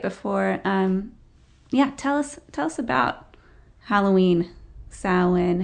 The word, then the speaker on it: Samhain: Well, and you mentioned Samhain, which is spelled, it looks like it's Samhain if people come across the word, but it's pronounced Samhain.